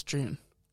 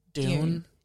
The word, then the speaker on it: Dune: You have to say it as Dune. Yeah. It's like an American inflection because otherwise it's June.